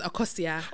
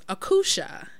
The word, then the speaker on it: Akosua: And it's spelled Akosua. Uh-huh. This person, for whatever reason, thought my name was Akusha. – Okay. – And I never corrected her.